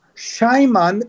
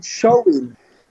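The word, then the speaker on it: Samhain: It dates back to the ancient Celtic festival of Shaiman, but you actually pronounce it Shoin.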